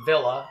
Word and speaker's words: Villa: Villa as in villa, but in Spanish, double L is pronounced like a Y. So it's Villa Hayes, and a department.